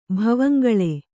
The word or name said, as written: ಭವಂಗಳೆ